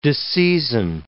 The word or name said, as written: disseizin